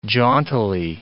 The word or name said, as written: jauntily